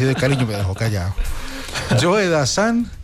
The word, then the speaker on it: Joe Dassin: Y entonces presentó a Joe Dassan como si fuera Joe Dassin, como se escribe. Joe Dassin. Entonces yo le dije, no olvides, Dianilla, que es Joe Dassan.